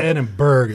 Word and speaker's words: Edinburgh: It's not Edinburgh. It's Edinburgh. You swallow that G, man.